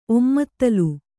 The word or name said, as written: ಒಮ್ಮತ್ತಲು